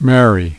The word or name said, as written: Mary